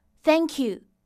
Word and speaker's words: thank you: 当我们发这样一个音的时候，需要同学们将舌尖放在上齿和下齿的中间，然后轻轻送气。请尝试跟我来读一下：thank。 Thank, thank you, thank you。